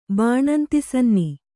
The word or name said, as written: ಬಾಣಂತಿಸನ್ನಿ